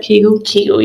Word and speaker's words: Kegel: Kegel, yeah.